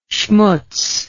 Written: shmutz

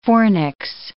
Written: fornix

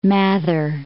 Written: Mather

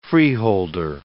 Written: freeholder

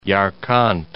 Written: Yarkant

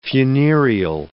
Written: funereal

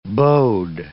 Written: bode

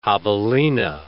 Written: javelina

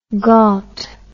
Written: ghaut

